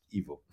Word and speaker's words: Ivo: And, of course, in English, you pronounce an E like an I and an I like an E. In German, or it's the other way around. But originally it is pronounced Evo.